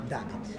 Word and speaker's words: Davids: Eh, con Davids, da, Davids o Davids? Come si pronuncia? Davids. Edgar.